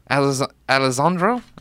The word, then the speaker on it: Alessandro: Today's song comes from Alessandro? Am I saying that? – Alejandro. – Right? No, it's got two S's. So Alessandro. – Alessandro. I said it right.